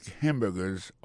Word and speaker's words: hamburger: Quand tu appuies et puis plus ça, c'est le premier syllabe là. I make hamburgers all day.